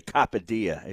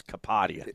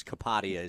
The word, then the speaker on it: Kapadia: So some say Kapadia. It's Kapadia. – Yeah. Well, my dialect will always mispronounce it. I apologize. That's the New Yorker in me. But yes, Kapadia is the correct pronunciation.